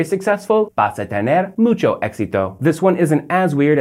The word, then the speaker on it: éxito: The word for success in Spanish is éxito, with the accent on the E.